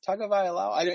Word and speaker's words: Tagovailoa: Najee Harris, Toa Tagovailoa. I can't remember his name, how to pronounce his name. – I'll give you the credit. You were close. – Tagovailoa.